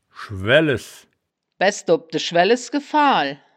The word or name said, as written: schwaelles